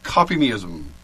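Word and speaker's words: Kopimism: Okay, well, it's not actually called the Pirate Church, but the Church of Copymeism, which is, yes, copy-me-ism, but it's spelled with a K, so it's not, it's still pretty lame.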